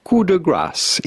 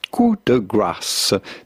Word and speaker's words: coup de grâce: Coup de grâce. In French, coup de grâce. Coup de grâce. But in English or American, coup de grâce.